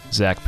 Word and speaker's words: Zach: My name, Zach Pape.